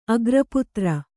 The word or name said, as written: ಅಗ್ರಪುತ್ರ